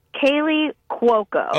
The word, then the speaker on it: Kaley Cuoco: Kaley Cuoco.